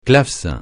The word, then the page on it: clavecin: francais